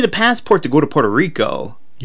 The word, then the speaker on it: Puerto Rico: And this week in the Craigslist Corral, we're taking a trip down to Puerto, say it right, Puerto Rico. – Puerto Rico.